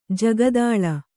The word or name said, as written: ಜಗದಳ